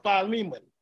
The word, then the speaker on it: Philemon: Paul, a prisoner of Yahusha, and Timothy, our brother, unto Philemon or Philemon. It's Philemon is how I pronounce it. P-H-I-Lemon our dearly beloved and fellow laborer, he, to our brother, to our beloved, um, uh, to our beloved Apphia and Ocphia.